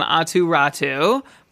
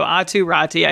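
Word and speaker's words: Aatu Räty: So Atu Rati, I think, is the realistic pronunciation.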